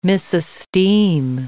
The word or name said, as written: misesteem